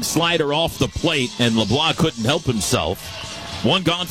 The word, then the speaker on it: LeBlanc: Another 0-2 from Mercurius to LeBlanc. Here it comes. Misses high and outside. L-E-B-L-A-N-C, the spelling, but it is pronounced LeBlanc. You do not pronounce the C on his name. He's a Louisianan. And you know, the E-A-U-X is down there and everything.